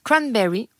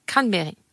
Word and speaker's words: cranberry: Elle est aussi appelée RL ou cranberry, à prononcer à l'anglaise cranberry ou à la française cranberry.